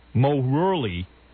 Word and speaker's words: Moehrle: In 1992, Williams met the woman who would become his second wife, Grace Morley. How do you say this? M-O-E-H-R-L-E. – I think it's like Morley.